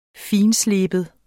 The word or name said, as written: fintsleben